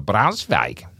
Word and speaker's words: Braunschweig: Petra Pasica from a German town of Braunschweig.